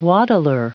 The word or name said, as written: waddler